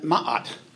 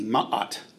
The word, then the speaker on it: ma'at: And in Egyptian, it meant ma'at. Well, that helped. Amen? Yeah. It's spelled M-A-A-T, ma'at. It's two syllables, ma'at. The word, among many other things, meant order. – What'd you say? – Order. O-R-D-E-R.